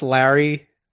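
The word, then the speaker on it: Lauri: Laurie, am I saying it right? Laurie Markkanen? – I don't know. I think it's Lowry. I think it's kind of like Kyle Lowry.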